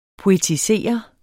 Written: POETISERET

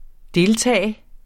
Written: deltage

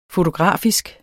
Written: fotografisk

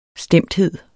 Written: stemthed

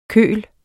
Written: køl

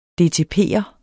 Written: dtp'er

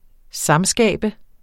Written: samskabe

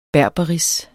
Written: berberis

